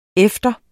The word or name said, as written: efter